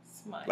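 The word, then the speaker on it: Smythe: It's pronounced smite. – Smite. – Like smite, like. Like, like smite. Smite somebody. Yeah.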